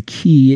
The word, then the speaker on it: chi: The whole idea of the key is that, and it's spelled C-H-I,